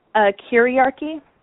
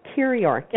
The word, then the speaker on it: kyriarchy: Kiriarchy. – Kiriarchy. – Yeah, it's spelled KYRIARCHY.